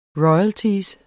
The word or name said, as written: royalty